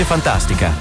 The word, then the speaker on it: Funtastica: Fantastica, si legge Fantastica, ma si scrive con la U.